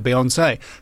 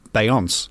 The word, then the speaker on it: Beyoncé: The acute accent on the final E of Beyoncé that makes it Beyoncé. So for the first year of being aware of Beyoncé, in my head I was pronouncing it Beyonce.